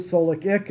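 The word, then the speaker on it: SOLIC: If you pronounce it an acronym, as the Pentagon is wont to do, Solik Ick, it sounds even worse than when you spell it out.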